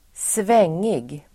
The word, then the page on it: svängig: [²sv'eng:ig]